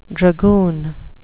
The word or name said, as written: dragoon